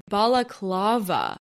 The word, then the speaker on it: balaclava: Balakava. Oh my god, I'm screaming. It's balakava. Balaclava. Balaclava. This word doesn't sound like a real word anymore. I'm screaming. Balaclava. That's how you say it.